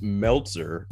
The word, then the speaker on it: Meltzer: You keep saying his name wrong. – I've he's never, not that important enough. He's not that relevant. He's not that relevant enough. – He's not that guy. It's Meltzer. And at first I thought you were just saying it like that on purpose to mock him, but no, you actually do that.